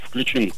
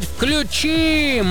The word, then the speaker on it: включим: Согласно строгой литературной, хочу подчеркнуть, норме, ударение в различных формах данного глагола сохраняется на том же слоге, что и в начальной. Поэтому правильно говорить «включим».